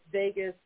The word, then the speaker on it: Vagus: And for anyone who's curious, I'm not 100% on the pronunciation, but Vagus, V-A-G-U-S is how it's spelled. – Yes. – Look up that nerve, V-A-G-U-S nerve,